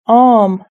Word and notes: arm: British English

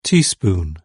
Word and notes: teaspoon: British English

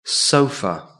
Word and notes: sofa: British English

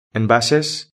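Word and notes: envases: Spain

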